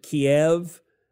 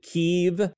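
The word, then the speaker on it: Kyiv: Everyone used to pronounce it Kiev and and now it's it's considered, I guess, more uh, enlightened to pronounce it Kiev. So I have to refer to one of my favorite dinners as chicken Kiev. Apparently, not chicken Kiev.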